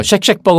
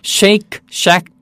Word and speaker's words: Shake Shack: 그래서 쉑쉑 버거가 아니고 Shake Shack Burger.